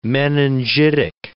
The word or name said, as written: meningitic